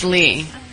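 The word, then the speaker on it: Lei: Spelled like the lay. – Exactly. – Like, what's the lay? – The lay, like a lay, like a L-E-I. – Like a Hawaiian lay. – Or pronounced Lee.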